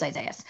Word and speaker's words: Isaias: And is it pronounced Isaias? Okay, it is Isaias.